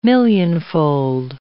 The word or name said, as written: millionfold